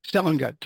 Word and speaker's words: Seligut: Seligut, just like it's spelled.